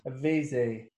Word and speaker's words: Avesi: Which is Avesi. – I don't know how you pronounce this because the accent's on the E in the middle. – Avesi. Yeah, it's like A.